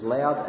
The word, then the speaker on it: laud: So, Ian, with the best Latin pronunciation, pronounced it as loud, and that would be quite correct if you were to pronounce it in Latin, wouldn't you? I think. So, however you like to pronounce it, loud as in Latin, or Lord as in English, it has the idea of praise.